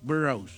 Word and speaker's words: Burroughs: ¿Cómo se dice en inglés? ¿Es el The Burrows? – A ver, déjeme ver, ¿cómo se escribe? – B-U-R-R-O-U-G-H-S. – The Burrows. – Burrows.